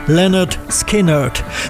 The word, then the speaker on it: Lynyrd Skynyrd: Im Titel der Scheibe liefern sie die korrekte Aussprache ihres Namens gleich mit. Pronounced Lynyrd Skynyrd.